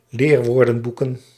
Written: leerwoordenboeken

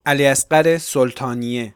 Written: Ali Asghar Soltanieh

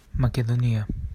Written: Makedonia

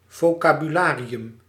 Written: vocabularium

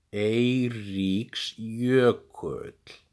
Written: Eiríksjökull

